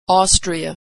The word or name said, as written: Austria